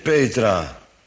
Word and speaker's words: Petra: En op deze Petra zal ik mijn gemeente bouwen. Onthoud dat, hè, waar de klemtoon moet liggen. En op deze Petra zal ik mijn gemeente bouwen.